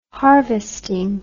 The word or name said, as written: harvesting